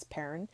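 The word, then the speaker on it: Perron: When the real-life Perrin family, I pronounced that incorrectly before, it's not Perrone, it's Perrin, family came to visit the set of The Conjuring, they were already on edge at how realistic everything was.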